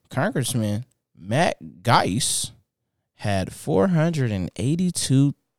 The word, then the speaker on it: Gaetz: So we had a Republican uh, Matt Matt Gaetz. Yeah, that's how you say his name.